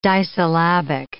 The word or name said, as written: disyllabic